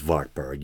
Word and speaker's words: Wartburg: I think it's Wartburg. Usually in German, the W is pronounced as a V, right? If there's any people fluent in German out there, please correct me if I'm wrong.